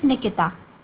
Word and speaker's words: Nikita: Yeah, my name is Nikita. – Can you spell that, please? – N-I-K-I-T-A.